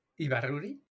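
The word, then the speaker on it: Ibárruri: And it represents, now again, help me with the pronunciation, Dolores Ibarrura. – Ibarruri? – Yeah, La Pasionaria.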